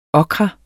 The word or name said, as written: okra